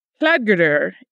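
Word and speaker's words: Hlaðgerðr: Her real name would have been in Old Norse and pronounced something like Hladgerdr.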